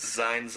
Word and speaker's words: zijn: Zine, Zine, Zine, Zine, because it's Z-I-J-N four times, and Z-I-J-N is Zine, which is Dutch for to be.